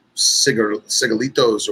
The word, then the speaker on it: cigarillos: But that being said, I love the Liga Privada number 9 so much that I will buy the little, you know, baby cigarillos or whatever they call them, mm-hmm. in a little tin. What's the proper pronunciation? – Cigarillos.